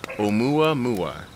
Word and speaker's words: Oumuamua: So our subject today is could possibly be the space probe that has left our solar system back in 2017, known as, and this is the pronunciation, Oumuamua.